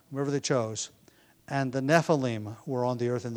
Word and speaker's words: Nephilim: But Nephilim is the Hebrew word transliterated. Just take the Hebrew letters and put them into English letters so we can pronounce it basically the way the Hebrews would.